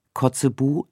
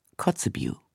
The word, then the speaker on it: Kotzebue: Wie die meisten hier Interviewten verwenden wir die deutsche Aussprache. Polowski statt Polowski, Kotzebue statt Kotzebue.